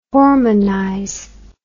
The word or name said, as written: hormonize